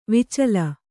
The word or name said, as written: ವಾಚಾಲ